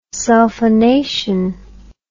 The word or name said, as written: sulphonation